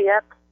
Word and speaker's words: Yep: They can Facebook me at Lilia Yep. It's just my name. It's spelled L-I-L-I-A, last name Y-E-P.